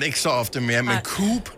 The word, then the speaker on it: Coop: Øh, min forlod har haft det med at udtale Coop for Coop, på mm. trods af, at øh, de sådan siger det korrekt i alle deres reklamer.